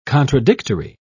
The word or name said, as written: contradictory